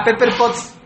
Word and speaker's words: Pepper Potts: A Pepper Pons, mejor conocida como Edith de Juárez. – Saludos, Edith. – Ah, Pepper Potts.